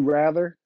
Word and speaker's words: Wrather: You have my personal page, which is Would You Rather, a little play on my name. For those who know, my last name is Rather, spelled with a W.